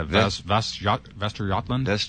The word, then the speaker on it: Västergötland: Västergötland? – Yeah, that's good.